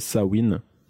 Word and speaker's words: Samhain: Ça vient de la fête celtique, donc Halloween vient d'une fête celtique qui s'appelle euh, Samhain, Sam Hain. Je sais pas comment on l'écrit, enfin comment on le prononce. Il y a deux. S-A-M-H-I-N. Ouais, mais euh, j'ai aussi écrit entre parenthèses Sawin. Donc euh, je pense que ça se ça se dit dans les, les, les deux dans les deux sens.